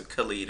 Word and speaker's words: Khalid: The video is by Khaled. – Khalid. – Is it Khalid? – It's Khalid.